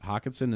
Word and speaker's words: Hockenson: Hockenson is a great – am I saying his name wrong? – No.